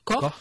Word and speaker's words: Koch: C'est parce que c'est effectivement un 24 mars que le bacille de Koch, alors en fait, je ne sais pas si on prononce Koch ou Koch. – Koch, qui est responsable de la maladie, a été découvert.